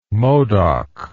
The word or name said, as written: modoc